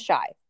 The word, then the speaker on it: Shai: His name is Shai. spelled like a chai latte, but with an S instead.